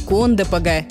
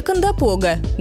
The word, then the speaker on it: Кондопога: Одно из таких – кондопога или кондопога. Давайте разбираться, как правильно. Слово это пришло из карельского языка, где произносилось с двумя ударениями. Более сильное падало на первый слог, а второе послабее – на предпоследний.